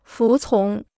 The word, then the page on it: 服从: fú cóng